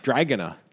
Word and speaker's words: Dragana: More dragon-like. It actually sounds like dragon. – Yeah. – Dragana.